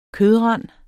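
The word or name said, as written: kødrand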